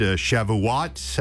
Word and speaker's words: Shavuot: You mentioned Shavuot.